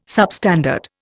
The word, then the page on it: substandard: Indian